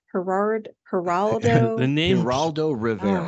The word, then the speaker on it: Geraldo Rivera: Geraldo Rivera.